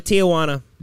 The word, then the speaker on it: Tijuana: De Tijuana.